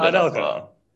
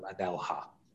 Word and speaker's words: Anelka: I didn't know if you were if I got the pronunciation right for you there, as well, with Nicola Anelka. – Nicola Anelka. And did he? I'm sure the guy on the CDVD called him Anelha at one point as well.